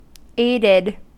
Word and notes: aided: American English

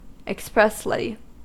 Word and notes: expressly: American English

